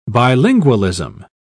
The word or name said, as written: bilingualism